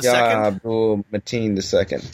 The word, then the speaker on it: Yahya Abdul-Mateen II: Yahya Abdul-Mateen II. – Yahya Abdul-Mateen II.